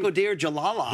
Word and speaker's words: Bakhodir Jalalov: Jalalov, I can never pronounce his last name. – Bakhodir Jalalov.